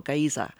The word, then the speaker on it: Geysir: But they've got some of the most beautiful waterfalls in the world, and You've got, of course, um, what they the original word Giza or Giza. That's what came from there. They spell it G E Y S I R.